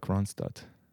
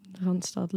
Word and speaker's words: Randstad: I would want to move somewhere in like Randstad. – Randstad, love the pronunciation. Randstad.